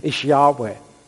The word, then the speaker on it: Yahweh: YHWH. The pronunciation is Yahweh.